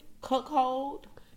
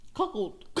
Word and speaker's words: cuckold: Cuckold. – It's no H in there. – Cuckold.